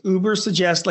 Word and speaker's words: Ubersuggest: Everything you ever want to know about your site and SEO, you're finding it on Uber Suggest. – So first of all, thank you. It's Uber Suggest, like Uber, like it has nothing to do with the car.